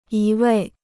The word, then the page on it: 一味: yī wèi